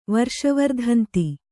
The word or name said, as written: ವರ್ಷವರ್ಧಂತಿ